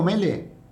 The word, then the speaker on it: Mele: Santiago Mele, que se escribe con una L sola. – Sí. – Yo creo que lo estoy leyendo con 2.